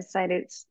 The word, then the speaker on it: CITES: Export permit, a CITES import permit.